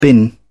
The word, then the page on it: bin: British English